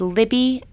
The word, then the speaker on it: Libby: And my last name is Libby, L-I-B, as in boy, B-Y, .com.